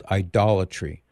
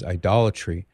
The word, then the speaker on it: idolatry: Typically you would hear it called idolatry because it has to do with idols. And it's, you know, splitting of hairs on how it's pronounced, but I think more people are going to recognize it as idolatry.